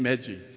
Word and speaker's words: Magi: But yeah, you would call them the Magi or the Magi. How would you pronounce it? The Magi. All right, my wife said Magi.